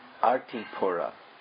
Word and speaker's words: Artipura: And there's a village nearby that is called Artipura. And the beginning part, the first syllable, is very similar to Arishta, like in Arishtasura, because it's the place where Arishtasura was killed.